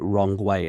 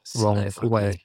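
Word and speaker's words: Rong Wei (荣威): But if you pronounce it in pinyin, then you say it Rong Wei. It sounds almost exactly the same as Rong Wei in English. – Rong Wei.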